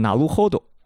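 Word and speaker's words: 成歩堂: 他的这个程步堂发音呢，特别像哪路 hold。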